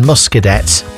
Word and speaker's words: Muscadet: Apparently, the final T in Muscadet is silent.